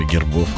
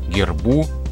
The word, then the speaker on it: герб: То тут ударение переходит на окончание во всех формах этого слова, в единственном числе, во множественном числе, в косвенных падежах, всегда на окончание. То есть герба, гербу, во множественном числе гербы, гербов, гербам. Ударение на окончание, это надо запомнить.